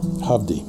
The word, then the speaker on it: Hovvdy: And then the last one is a band called it's Hovdy. – Come again? – Hovdy.